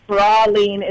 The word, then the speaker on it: praline: I hate to school y'all and everybody who already called, but it's praline is the proper pronunciation because I Googled it. But it also is pronounced differently depending on where you are. But Georgians here, we'd love to say praline.